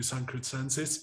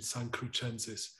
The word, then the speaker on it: Sancrucensis: So in German pronunciation it would be San Crucensis, but in Italian pronunciation San Crucensis, which is the adjectival form of the name of my monastery.